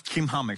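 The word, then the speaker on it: Chimham: It's pronounced as Kimham, actually, in Hebrew.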